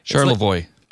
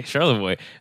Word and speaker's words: Charlevoix: It's a ferry ride north of Charvelos, I believe. – Charlevoix. – Okay, Charlevoix. I should have asked you to pronounce that one as you're a Michigan person.